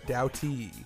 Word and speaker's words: Doughty: Doughty.